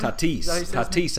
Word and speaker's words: Tatis: The one in particular is Fernando Tatis Jr. Is that right? – Tatis, I believe, is how they say it.